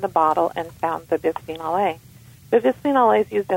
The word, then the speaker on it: Bisphenol A: Well, the one that comes to mind off the top of my head is bisphenol A, although that is currently controversial, but it is very, very widely spread throughout many kinds of products.